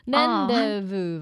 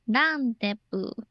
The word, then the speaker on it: rendezvous: Rendezvous 吧，应该因为 Z 不发音，然后 S 也不发音，好，可能是法文吧，会合的意思。 – 嗯，OK，嗯，我也是第一次看到这个片假名。哦，对，总之呢，这个一休一生 make you 一生呢，在迷宫里面回游会合 Rendezvous，就是啊，在迷宫里面。